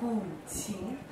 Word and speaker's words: guqin: The one I just played, the smaller one called the qin, Q-I-N. Would you like to try to pronounce it? Qin. – Qin. – Yes, qin. We also call it gu qin. Can you try it? Gu qin. G-U-Q-I-N.